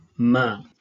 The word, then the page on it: m: Sanskrit